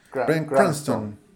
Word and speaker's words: Cranston: Cranston.